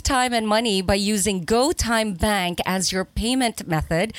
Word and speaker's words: GoTyme: It's GoTime. It's spelled GoTyme.